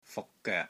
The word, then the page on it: FUK6 GOEK3: Cantonese